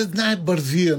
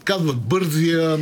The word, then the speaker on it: Бързия: Ами защото то си се знае Бързия, нали, в нашия. – Да. – Но пък хората се бъркат, казват Бързия, нали. – Особено моите хора нещо с ударенията. Виждам, че имат проблем, така че е хубаво, че има ударение.